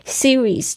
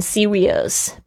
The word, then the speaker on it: series: Series 就是指一系列，所以呢，大家注意一下，很多同学会念成 serious，不是 serious，是 series。